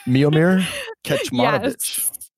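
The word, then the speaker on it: Kecmanović: Now, the hardest one for me to pronounce, Kecmanovic. – Yes. – Is that? Did I get it? – Miomir Kecmanovic.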